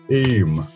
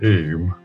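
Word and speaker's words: Eyam: So this is 1666, the year after the Great Plague in London, and plague had arrived in the Derbyshire village of Eme, is how the locals pronounce it, Eme.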